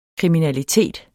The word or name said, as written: kriminalitet